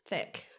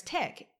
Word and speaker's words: Thek: I remember my coach, Pat, he thought my last name was pronounced Thek, because it's spelled T-H-E-K for those who don't know. And I think he overheard someone say, oh yeah, Grace Thek, and he had to correct them. No, it's Grace Thek.